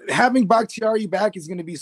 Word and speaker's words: Bakhtiari: How do I say his name? For the Packers, the left tackle, David Bakhtiari. – Bakhtiari. – Yeah, there you go.